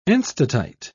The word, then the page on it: enstatite: British English